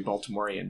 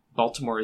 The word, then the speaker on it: Baltimore: And moving to Baltimore, I, you know, listeners to the podcast will know that I'm not a true Baltimorean because I pronounce it with the hard T instead of Baltimore.